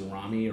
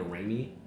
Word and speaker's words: Rami: Like I didn't really know how to pronounce my name properly for the longest time. – Okay. – Like, I was just like used to Rami or Rami.